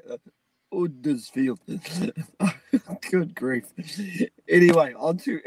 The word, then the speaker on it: Huddersfield: My mum is from Yorkshire, and here she goes to tell you how to say Huddersfield. – Don't say Huddersfield or Huddersfield. It's Huddersfield. – Huddersfield. – Now, that sounds really stupid. So you don't do an H, you do Huddersfield.